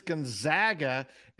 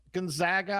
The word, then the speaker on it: Gonzaga: Yes, to my knowledge, it is Gonzaga. And the reason is they're also known as the Zags. They're not known as the Zogs.